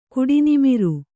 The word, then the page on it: ಕುಡಿನಿಮಿರು: Kannada